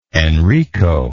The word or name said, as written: enrico